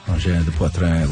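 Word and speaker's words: Angine de Poitrine: And then I got a text from our friend Louise, who said the last syllable of Engin de Poitrin is pronounced like in because of the E at the end. Engin de Poitrin, like in.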